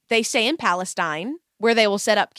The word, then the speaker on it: Palestine: So it's Palestine, Texas, but they pronounce it Palestine in Texas, but it's spelled Palestine, comma Texas.